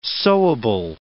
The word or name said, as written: sewable